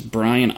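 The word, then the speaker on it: Brien: My name's Brian Odland. Brian spelled a little bit different than the usual, but it's B-R-I-E-N.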